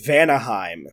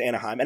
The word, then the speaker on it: Vanaheim: Thor, meanwhile, is on Vanaheim, which I was so disappointed to find out. – It was never written. And it was barely said, and so I thought it was Anaheim, and I'm sticking with Anaheim.